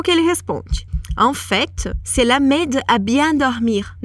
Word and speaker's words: en fait: É o en fait. Quando a gente fala en fait, a gente pronuncia esse T no final, que normalmente não é pronunciado.